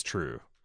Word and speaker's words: Triu: Forgive me if I mispronounce this because I just saw it in writing. Triu? – I think it's actually, I looked up several like recountings of this legend on YouTube and they say it's true. It's pronounced true. – As true.